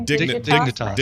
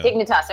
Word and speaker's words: Dignitas: Dignitas. Dignitas, yeah. – Dignitas.